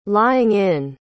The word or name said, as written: lying-in